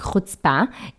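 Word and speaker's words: хоцпа (חוצפה): это слово «хоцпа». А, на иврите это произносится как «хоцпа», и это наглость, это такая отличительная черта израильтян, просто э, израильского менталитета.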